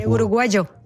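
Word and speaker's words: uruguayo: De Mauro, il dizionario, registra un uruguagio che viene dallo spagnolo americano, si scrive uruguaio con la Y, ma in Uruguay si legge uruguagio questo modo di mettere la bocca.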